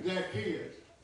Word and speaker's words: Zacchaeus: And behold, there was a man named Zacchaeus. How do you pronounce that? – Zacchaeus.